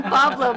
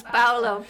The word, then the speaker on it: Paulo: And we talked about the fact that he doesn't understand why people call him Pablo because his name is Paulo, P-A-U-L-O. And I said, well, it's probably because they're automatically assuming the Spanish pronunciation is Pablo. Paulo, I think Italian, right? – Yep.